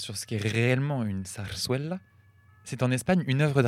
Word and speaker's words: zarzuela: Alors, je tiens à dire d'abord que nous avons toutes mal prononcé parce que les deux Z se prononcent et le U se prononce ouais. Donc on dirait plutôt zarzuela.